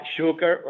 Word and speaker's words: Šuker: Šuker, right? Did I say that right? – You got it right.